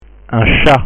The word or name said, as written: un chat